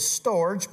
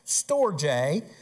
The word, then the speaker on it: storge: If you looked at it, you, uh, you would automatically think it was storge, but it's pronounced storge.